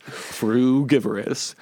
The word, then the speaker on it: frugivorous: Frugivorous.